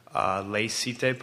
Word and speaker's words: laïcité: I think many would agree that France is a unique example with regards to Muslims living in Europe because of the laïcité policy, I don't know if I'm pronouncing it right, yep.